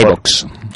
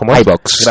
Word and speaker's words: iVoox: ¿Qué tú lo has pronunciado mejor. – iVoox. – ¿Cómo es? – iVoox.